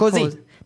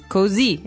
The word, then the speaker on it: così: Remember, the accent is on the last vowel of that, so not cozy. – Not cozy, it's così.